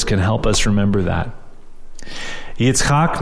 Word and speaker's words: Yitzchak: Yitzchak is maybe a more correct way of pronouncing it in Hebrew.